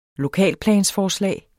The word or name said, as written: lokalplansforslag